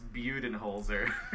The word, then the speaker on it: Budenholzer: Does it help at all that it's actually pronounced Budenholzer? – No, that's worse.